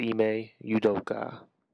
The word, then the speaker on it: Ime Udoka: Which, by the way, is Ime Udoka.